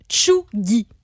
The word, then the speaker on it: cheugy: Ça s'écrit C-H-E-U-G-Y, mais ça se prononce. C-H-E-U-G-Y, mais ça se prononce Chugy.